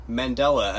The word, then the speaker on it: Mandela: Mandela, as in Nelson.